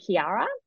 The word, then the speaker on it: Qiara: is called Kiara, and that's spelled Q-I-A-R-A.